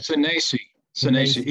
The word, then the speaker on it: Sinesi: Sinesi. He sound like A's.